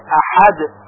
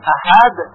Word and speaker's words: احد: قل هو الله احد، کلمه احد هم با های جیمی هست و با همون ها هم باید تلفظ بشه احد، پس اگر بگوییم احد غلط هست.